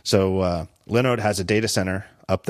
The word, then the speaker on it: Linode: I pronounced it Linode the first time they sponsored because that's what it looks like, Linode. But of course it's pronounced Linode because it's Lin as in Linux.